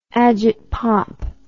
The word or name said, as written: agitpop